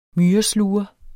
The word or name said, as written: MYRESLUGER